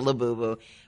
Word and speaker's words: Labubu: Have you heard of, and I hope I'm pronouncing this right, the Labubu toy, L-A-B-U-B-U? – Labubu, yeah.